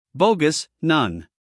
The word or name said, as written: bogus